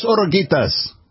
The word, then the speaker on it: oruguitas: Dos oruguitas. Did I say that right? – Yes.